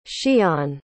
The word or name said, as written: xian